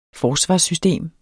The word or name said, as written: FORSVARSSYSTEM